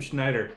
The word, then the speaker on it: Schneider: E-K-I-M. Ekim. Ekim Schneider. – How do you spell the last name? What? – Yeah. – S-C-H-N-E-I-D-E-R.